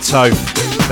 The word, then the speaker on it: Toph: My name is DJ Toph. That's T-O-P-H. Rhymes with a loaf. Don't ask me why. It just does.